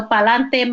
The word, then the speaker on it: pa'lante: So pa'lante, my people, mi gente, pa'lante, siempre pa'lante.